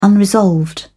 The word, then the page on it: unresolved: British English